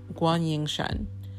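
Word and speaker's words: Guan Ying Shan (关颖珊): But anyways, she tweets out, hashtag, "My name is Michelle Wang Kwang, and in Chinese pinyin, it's pronounced Guan Ying Shan.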